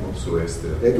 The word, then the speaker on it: Mopsuestia: Theodore of Mopsuestia. Did I pronounce that right? – Mopsuestia.